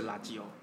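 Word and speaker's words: ラジオ: 其实垃圾油就是英文的 radio 的意思，只不过用日文发音的话就是垃圾油。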